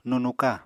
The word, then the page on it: nunuka: Apatani